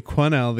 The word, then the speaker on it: Quesnel: Actually, I hate to correct you, but it's actually Quesnel. The S is silent because I have dirty French Canadian blood from way back.